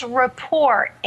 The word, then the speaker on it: Rapport: I mispronounced Wendy Pease's company. It's Rapport International. Sorry about that, Wendy. – Oh, that's okay. – Okay, common mistake, but it's the French word for rapport.